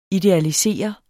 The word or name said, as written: idealisere